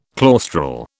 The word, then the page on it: claustral: British English